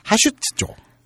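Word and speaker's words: 하슈츠쪽 (派出所): 그게 고치라 카즈시카고 카메아리 공행 마에 하츠스츠쪽 발음 이상하다. 하슈츠쪽 파출소. 음.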